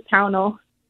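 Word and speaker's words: Pownal: Hi, Patrick. It's Pownall.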